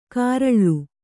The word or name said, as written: ಕಾರಳ್ಳು